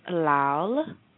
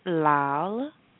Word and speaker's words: lal: I-L-A. And it's pronounced pila. The second color is going to be red, and red is pronounced lal. I'll say it again, lal, and that is spelled L-A-L-A. So it's spelled like lala, but it's pronounced lal.